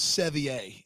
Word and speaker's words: Sevier: Severe weather warning, who I believe they've told us is Sevier, is how that name is pronounced.